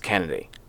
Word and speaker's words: Kynadi: And she spells her first name K-Y-N-A-D-I. So, but it's pronounced Kennedy.